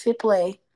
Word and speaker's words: Hiplet: So it's pronounced Hiplet. I of course said, did I say Hiplet? Well, it's Hiplet.